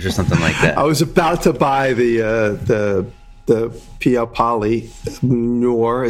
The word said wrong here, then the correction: Noir: Noor? It looks like... – Sounds like you're speaking a different language. Piappali Noir.